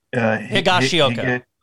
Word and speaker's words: Higashioka: Yes, he's probably going to be, you're going to have to help me with the pronunciation, platoon, matchup platoon with Higashioka. – Thank you.